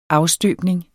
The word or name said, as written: afstøbning